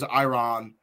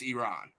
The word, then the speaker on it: Iran: That reporter asked, he was first off, you said our country was Iran, Iran. It's not. It's Iran.